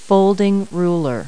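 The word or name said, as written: folding rule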